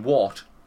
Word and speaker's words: wort: And that's spelt differently, but up here, wort and wort, they're the same thing. – Yeah. – Wort with an O and wort with an A are both pronounced wort.